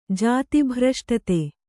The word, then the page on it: ಜಾತಿಭ್ರಷ್ಟತೆ: Kannada